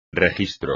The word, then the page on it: registro: espagnol